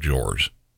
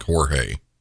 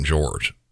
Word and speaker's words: Jorge: You'll hear later on in the interview with the detectives that Sarah says she loved George. And I say George even though if you look at his name, you might think Jorge. Sarah called him George.